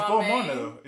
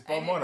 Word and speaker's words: Balmain: Is it Balmain or Balmain? – No, it's Balmain. It's Balmain apparently. – It's Balmain. – It's Balmain. – Because I thought it was Balmain, but then it's actually Balmain.